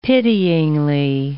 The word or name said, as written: pityingly